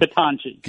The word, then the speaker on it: Ketanji: So let's focus just on Judge Kente Brown. Maybe I'm mispronouncing. I'm not trying to. – Ketanji. Yeah, Ketanji Brown Jackson.